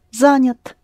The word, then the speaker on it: Занят: Я, but it's not accented, so you go past it quickly. You don't notice it's Я, it's somewhere in between. Занят. – Занят. – Mm-hmm. But if you want to show how it is spelled, then you would pronounce it Занят. And then the person would know that you mean Я.